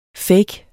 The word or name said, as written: fake